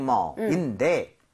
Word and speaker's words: mall: 대신에 mall보다는 A-L-L 혹은 A-L 스펠링은 이 모양이 벌려져야 돼요. 그래서 all에 더 가까워요.